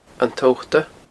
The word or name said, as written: An Tobhta